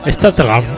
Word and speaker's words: Statham: Jason Statham.